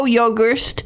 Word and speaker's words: Yogerst: It's Joe Yogerst, spelled with Y-O-G-E-R-S-T, Yogerst.